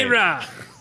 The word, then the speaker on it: Akira: Oh, say it right. – Akira. – You want vintage? Akira. How about this? – It's fantastic.